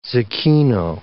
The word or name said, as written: zecchino